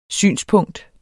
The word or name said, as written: synspunkt